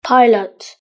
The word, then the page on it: pilot: British English